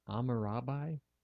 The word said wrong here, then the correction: Hammurabi: Hammurabi. – Did they have such thing as silent letters back then? – I wasn't completely omitting the sound.